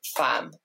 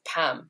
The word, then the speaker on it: Pham: I know my surname, it's pronounced Pham, like P H a M. Um, and for some reason, people pronounce that as Pam. They just miss that there is a P H.